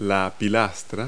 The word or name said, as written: pilaster